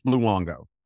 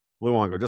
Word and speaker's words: Luongo: It's Luongo, right? – Luongo. – Just like the cult. Luongo, just like it's spelled.